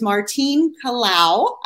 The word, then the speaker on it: Martine Kalau: Our guest today is Martine Kalau.